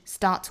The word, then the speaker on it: starts: No matter what type of animal you are, 而不能读成 you are, change starts with you, change starts with you. 这个 change 仍然是双元音 a 的发音 make, change, make, change. Starts with you, 这个 start 加上 s, 尾部呢读成 ts, starts, 就好像我们说一次两次的那个次哈，取它的声母 starts with you, starts with you.